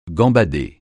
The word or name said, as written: gambader